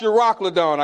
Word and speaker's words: Euroclydon: Wind called Eurocyclone. Oh, Euroclydon. I went, who? Euroclydon. That looked like Euro to me. That's Euro. But it's pronounced Euroclydon, I guess.